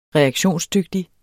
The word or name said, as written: reaktionsdygtig